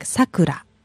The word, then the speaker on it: Sakura: But pronunciation for the blossom is Sakura. It's kind of flat. But for name, it goes down like Sakura.